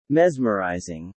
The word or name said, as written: mesmerizing